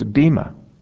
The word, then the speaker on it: bema: And if you look up the Greek word that's used here, it's bema. And it's B-E-M-A with a long line over the E. So that's what it looks like when it's pronounced in the Greek.